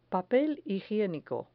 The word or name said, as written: papel higienico